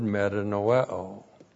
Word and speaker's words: metanoeo: S-H-U-V, shuv, to repent, and the Greek word metanoeo. M-E-T-A-N-O-E, long O.